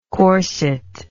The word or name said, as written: corsetless